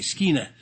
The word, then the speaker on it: skene: The Greek word is skene, and they're long e, skena, and it's used in the Greek Old Testament in the Septuagint.